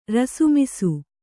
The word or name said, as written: ರಸುಮಿಸು